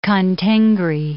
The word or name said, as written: Khan-Tengri